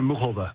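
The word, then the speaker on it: Muchová: And she says Karolina Muhova. Muhova. That's how she pronounces it.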